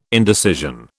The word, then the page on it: indecision: British English